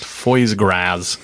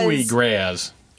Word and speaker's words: foie gras: Yeah, it's definitely pronounced foie gras.